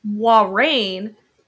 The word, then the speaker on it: Moiraine: Yeah, so the whole, and this isn't necessarily a spoiler, what those swirling lights that are going around, which I just learned this is how you pronounce her name, Moiraine, I'm still going to call her Moiraine.